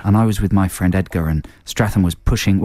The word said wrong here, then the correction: Statham: How do you say his name again? – Statham.